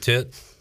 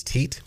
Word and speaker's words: teat: So, you're okay with tit? – I would prefer the correct pronunciation of that is teat.